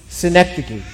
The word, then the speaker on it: synecdoche: And she told me, synecdoche.